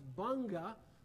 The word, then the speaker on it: Bong: Going up in the Bong County, this is pronounced Bunga,